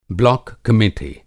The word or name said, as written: ब्लाक कमिटी